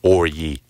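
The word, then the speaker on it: Orji: That's his name. It's O-R-J-I. There's no other way to pronounce it. – Orgy.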